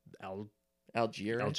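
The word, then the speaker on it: Allgeier: Because he's either going to work out or they're going to split between Damian Williams and the rookie, Tyler Algier. – I believe is how we would pronounce that.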